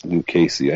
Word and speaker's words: Lucchesi: Yeah, that one I saw in the media guide from the Storm that has the pronunciation, so it's Lucchesi.